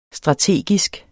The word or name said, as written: strategisk